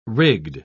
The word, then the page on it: rigged: British English